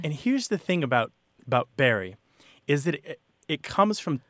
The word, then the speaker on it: Bury: Most people, it's something like 99% of people in the English-speaking world pronounce it as Barry.